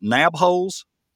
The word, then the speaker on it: Nabholz: I'm going to try to pronounce this. Is it Nabholz? – Yes, sir.